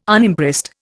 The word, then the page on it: Unimpressed: Indian